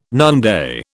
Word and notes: noonday: British English

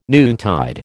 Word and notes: noontide: American English

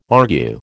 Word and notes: argue: American English

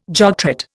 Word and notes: jogtrot: Indian

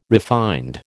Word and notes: refined: American English

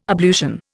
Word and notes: ablution: Indian

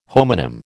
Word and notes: homonym: American English

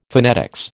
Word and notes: phonetics: American English

